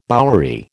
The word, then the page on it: bowery: American English